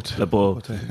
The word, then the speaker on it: Laporte: Laporte. What did I just butcher his name? – Laporte.